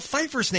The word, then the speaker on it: Pfeiffer: And I got to say, Party Bus Kevin, you pronounced Michelle Pfeiffer's name correctly, and that shocked me. – Well, I'm a huge Michelle Pfeiffer fan. – Well, it's got that silent P there in the last name.